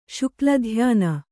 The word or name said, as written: ಶುಕ್ಲಧ್ಯಾನ